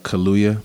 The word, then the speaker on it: Kaluuya: Uh, Kaluuya.